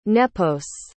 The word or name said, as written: nepos